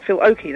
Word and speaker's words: Oakey: Oh, is it not with an L? Is it not Oakley? – Oh, it's Phil Oakey, Human League.